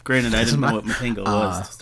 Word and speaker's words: Matango: M-A-T-A-N-G-O. So if it's still supposed to have a Japanese pronunciation, obviously it would be Matango.